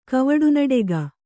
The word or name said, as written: ಕವಡುನಡೆಗ